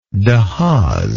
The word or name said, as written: de haas